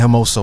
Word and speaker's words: Hermoso: The player responsible for that goal, Jennifer Hermoso, I believe. Jenny Hermoso, am I saying it right? – Yeah.